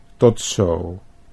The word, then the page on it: tot zo: Dutch